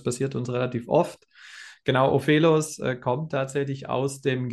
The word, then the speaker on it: Ofelos: Ähm, ich hoffe, ich spreche ihn richtig aus. Ophelos. Ähm, was ist das? Was bedeutet das? – Ja, also fast richtig ausgesprochen. – Äh, Ophelos. Ah, äh, okay.